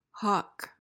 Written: hough

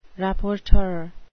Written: rapporteur

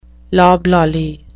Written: loblolly

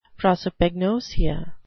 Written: prosopagnosia

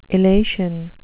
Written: illation

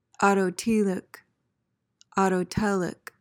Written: autotelic

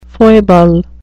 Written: foible

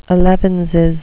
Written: elevenses

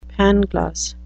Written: pangloss

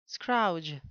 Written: scrouge

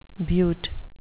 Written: butte

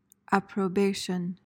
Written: approbation

